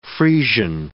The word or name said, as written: Friesian